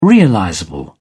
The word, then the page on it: realizable: British English